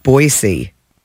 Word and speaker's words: Boise: Boise.